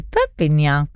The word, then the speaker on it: Perpignan: Perpignan? Did I pronounce that correctly? Perpignan. You can correctly.